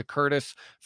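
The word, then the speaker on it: Courtice: But then it goes like Pickering, Ajax, Oshawa, Curtis, if you will. – That's right. – And Curtis isn't spelled like Curtis's name. It's like Cortice. It's the most fucked up way to spell Curtis of all time.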